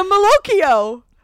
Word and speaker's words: malocchio: It's called the evil eye. The malocchio. – I think it's just pronounced the maloic. – It's M-A-L-O-C-C-H-I-O. Sicilian Americans have been raised to believe in the powers of the malocchio. – I don't think you're saying it correctly, but yes.